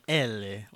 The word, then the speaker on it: Eli: They do say it like Elle, like they Elle, yeah, it's right. It's Swedish, yeah.